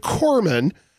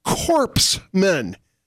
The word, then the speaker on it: corpsman: At a prayer breakfast talking about the military, Obama kept mispronouncing the word corpsman by articulating it phonetically, corpsmen. He did say it quite a few times.